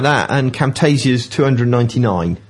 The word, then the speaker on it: Camtasia: Camtasia.